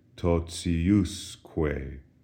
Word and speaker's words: totiusque: Notice the accent shifts from the T-I syllable to the U-S syllable. And as a result, the pronunciation of T-I is no longer T as in totius, but rather it softens. We have an S in between the T and the I, and so it sounds like this, totiusque.